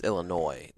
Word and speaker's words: Illinois: The state of Kent is leading Illinois 10-3. – It's Illinois. The S is silent.